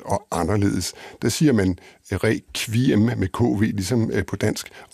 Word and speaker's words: requiem: Vi kunne sagtens synge med flade danske ager, siger han, men vi synger med flotte, runde ager, og vi ruller på ageret og synger requiem med den latinske QU-udtale. Og samme italienske udtale bruger man altså også i de engelsktalende lande.